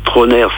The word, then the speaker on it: Trauner: Trôner, ça s'écrit T-R-A-U-N-E-R.